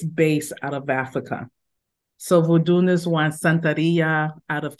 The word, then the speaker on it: Vodou: So the spiritual tradition in Haiti is pronounced as Haitian voodoo, V-O-D-O-U, voodoo. However, the origin and foundation of that tradition is out of West Africa, Benin, and it's called voodoon.